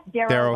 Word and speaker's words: Darrow: Am I saying that right? Is it Darrow Wealth Management? – Yep, Darrow Wealth Management.